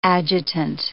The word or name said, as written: adjutant